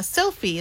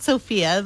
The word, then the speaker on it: Sophie: Sophie? Yeah, Sophie. That's how you say it. It's not Sophia. There's no A at the end. It's Sophie.